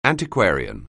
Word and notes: antiquarian: anglais

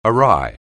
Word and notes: awry: anglais

